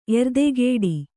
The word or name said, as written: ಎರ್ದೆಗಿಡು